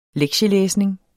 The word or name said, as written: lektielæsning